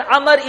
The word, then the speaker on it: Amr: So, to make a difference between Umar and Amr, the word Amr is written with a waw at the end, which makes it 4 letters. Although it's not pronounced, it's a silent letter, but it makes it 4 letters.